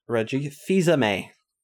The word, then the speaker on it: Reggie Fils-Aimé: Reggie Fils-Aimé.